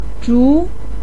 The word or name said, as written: zhu2